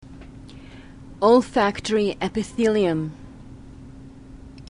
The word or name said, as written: olfactory epithelium